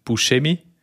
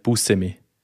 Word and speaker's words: Buscemi: Ja, Nachtrag zum Steve, wie man auch immer ausspricht, auf Italienisch, ich habe es wild geschaut, extra zu dem, auf Italienisch original hat er gesagt, spricht man schon aus, Buscemi, aber er ist irgendwie nicht so aufgewachsen und darum er selber nennt sich irgendwie Buscemi.